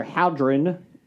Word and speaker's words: Hadron: The European Organization for Nuclear Research, CERN, recently announced they will be starting up their Hadron. – Thank you for not pronouncing it hard on.